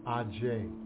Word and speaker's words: Aje: When a Yoruba person or someone who can read Yoruba sees the letters A, J, E, the first thing that comes to mind is Aje. Aje meaning witch. However, A, J, E can also be pronounced A-J.